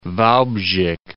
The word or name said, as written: Walbrzych